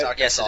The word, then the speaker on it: Arkansas: I think it's Arkansas. – Yes, it is.